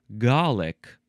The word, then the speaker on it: Gallic: All right, next we have Gallic acid. Two words. You can also pronounce it garlic.